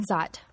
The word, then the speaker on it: Szot: Zott. – Z-O-T-T? – S-Z-O-T. The S is silent.